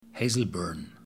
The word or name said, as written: Hazelburn